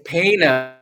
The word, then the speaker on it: Pana: It's Pana. Don't feel bad. Pana. We butchered it about 1,000 times when we came here, but it is pronounced Pana.